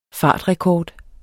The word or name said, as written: fartrekord